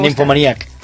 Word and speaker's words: Nymphomaniac: Que heu sabut com es pronunciava i tot, eh? – Sí. – Nymphomaniac. – Nymphomaniac. – L'està fent a l'anglesa, sí. Jo li havia proposat que ho fes a la danesa, ja que Lars von Trier és danès.